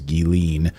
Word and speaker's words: Ghislaine: We knew that there would be another shoe to drop in the Ghislaine Maxwell Jeffrey Epstein saga. And yes, that is how you say her name, according to her brother who published an opinion piece. It is Ghislaine Maxwell.